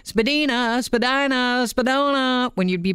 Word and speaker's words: Spadina: And Spadina is pronounced Spadina. It's a native yes. word meaning uphill, not a spadina.